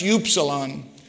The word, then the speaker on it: upsilon: It's pronounced upsilon.